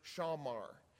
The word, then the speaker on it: shamar: When we read here in these two verses the word keep, in Hebrew, it's actually the word shamar. Shamar is spelled S-H-A-W-M-A-R. Actually, you can drop the W. I misspelled that. S-H-A-M-A-R. S-H-A-M-A-R is how you spell shamar.